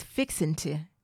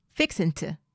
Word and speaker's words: fixin' to: The next one is fixin' to. So remember I said earlier that we do not pronounce the G. So it's fixing to. Fixing to. Fixin' to.